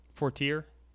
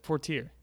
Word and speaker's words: Fortier: You trying to pronounce. – Oh. Fortier? Maybe that's it. Fortier. – How's it spelled? – F-O-R-T-I-E-R. – Fortier? – Yeah. Maybe.